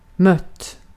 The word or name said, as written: mött